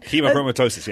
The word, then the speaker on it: hemochromatosis: hemochromatosis. Did I pronounce that right? – Hemochromatosis, yes.